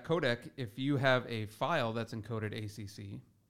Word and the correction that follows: AAC: right? – AAC. Yep. – Sorry, I keep saying that incorrectly.